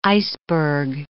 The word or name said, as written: iceberg